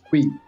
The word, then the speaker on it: Cully: I think they call it Cui, but it's spelled as C-U-L-L-Y, one of the greatest festivals in Switzerland.